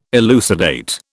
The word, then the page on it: elucidate: British English